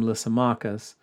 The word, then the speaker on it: Lysimachus: Uh, I I pronounce his name Lysimachus, who took Thrace and much of Asia Minor, and then Seleucus, who took Syria, and Ptolemy, who took Egypt.